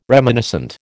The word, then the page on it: reminiscent: American English